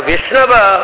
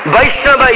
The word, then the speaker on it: Vaishnava: But a Vishnava actually is not good pronunciation. Vaishnava is perfect pronunciation.